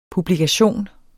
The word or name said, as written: publikation